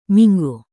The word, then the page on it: minggu: Indonesian